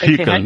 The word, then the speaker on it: Hückel: ¿Y por qué pronuncias Hückel? Lo presentas como con una I, ¿no? – Porque no se me da muy mal pronunciar el alemán. Es Hückel, ¿no? – En alemán lleva una diéresis, sería una U. – Hückel. Una U pura, sí, Hückel, vale. – Pero algo así. Suena más alemán, hay que pronunciarlo en plan alemán. Claro, pero básicamente se parece mucho más a una U.